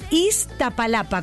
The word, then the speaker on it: Iztapalapa: O es Estapalapa, a ver. – Estapa. – ¿De dónde son ellos? – De Iztapalapa. – ¿Cómo se escribe? – Iztapalapa, con Z.